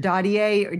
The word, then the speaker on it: Dadier: Number two, Lily Christabel Moffat, that's M-O-F-F-A-T-T, 1945. Number three is Richard, I'm not sure if, I think it's Dadier, Richard Dadier, or D-A-D-I-E-R, 1955.